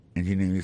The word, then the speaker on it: engineering: Engineering, ça, l'accent tombé le même sur avant dernier syllabe là.